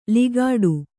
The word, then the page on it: ಲಿಗಾಡು: ligāḍu